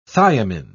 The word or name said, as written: thiamine